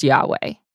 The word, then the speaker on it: Yahweh: Debate surrounds the pronunciation of the name. However, most Hebrew scholars would agree that it is Yahweh.